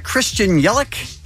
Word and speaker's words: Christian Yelich: Tomorrow morning on All New Kevin and Bean Show, we have Christian Yelich. Did I say his name? Plays for the Brewers? – Yeah.